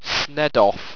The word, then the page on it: sneddof: owstwecian